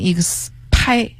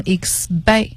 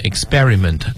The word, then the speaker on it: experiment: 好，这个单词呢咱们先说一下读音，虽然是 exp p for Peter，但是读音的时候它浊化了，不念成 expi 而念成 expi。 – 所以呢拼写和读音它是有一些技巧的哈。你看这个 experiment 读是这么读，但你拼写的时候不能把 p 写成 b。